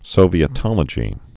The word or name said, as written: Sovietology